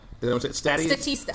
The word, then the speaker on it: Statista: Statista. – Statista. – Yes. – Like Batista. – Like Batista, uh-huh. Only with stats.